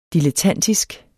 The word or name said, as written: dilettantisk